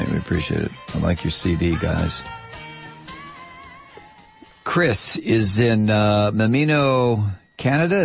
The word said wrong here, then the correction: Nanaimo: Is that right? Mimino? – Nanaimo. – How do you say it? – Nanaimo. – Nanaimo. We're not even close here.